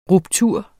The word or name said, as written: ruptur